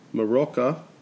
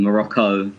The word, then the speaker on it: Morocco: Iran, not Iran, and Morocco, not Morocco. Morocco is like a drink.